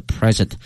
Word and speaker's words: present: 所以大家看到 the present 它常用的意思呢有三类啊。接下来看看这个单词，它作为动词的发音是 present. – 哎，这个重音放到第二个音节了，大家要特别的注意啊，present。 – 哎，present 这个词哈，那么这个词呢，如果大家小心留意的话呢，会经常听见。